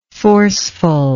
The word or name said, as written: forcefulness